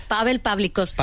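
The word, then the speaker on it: Paweł Pawlikowski: Nunca sé cómo se dice, Pavel... – Pavel Pavlikovsky. – Pavlikovsky, que es la rehostia, lo cual me alegro muchísimo.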